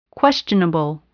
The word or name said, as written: questionable